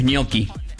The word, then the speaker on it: gnocchi: Ponen gnocchi. – A ver, ¿qué pasa? – Que la pronunciación de gnocchi en italiano es gnocchi.